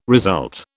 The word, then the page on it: result: British English